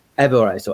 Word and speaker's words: Averroes: Averroes, or Averroes.